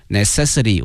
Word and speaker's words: necessity: 我们学过它的使用词哈，非常的熟悉 necessary，但是呢变成了名词哈，它的发音呢多少有些变化，叫 necessity，necessity， necessity。